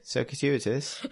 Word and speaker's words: circuitous: Just in my own circuitous way. – Circuitous? – Is that how you pronounce that word? – Circuitous. – I thought there was an extra T.